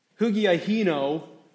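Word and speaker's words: Hugiahino (ὑγιαίνω): Hugiahino, if you hear it in there, you hear somewhere in there, hygiene. Do you hear it? Not the way exactly the way it's usually pronounced, but actually I think the English word hygiene comes from this word hugiahino.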